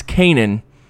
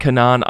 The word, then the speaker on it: Kanan: So, it's, it's Kanan not not Kanan.